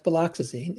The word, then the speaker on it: viloxazine: What can you tell us about Viloxazine. Is that how it's pronounced? – Viloxazine? Yes, it's it's it's uh, viloxazine or extended release viloxazine because it's uh,